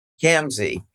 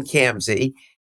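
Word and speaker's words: Khamsi: It's Kamsey, so you can just take the H away and just say it rhymes with ham. – Kamsey, Roxanne Kamsey.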